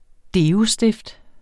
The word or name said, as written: deostift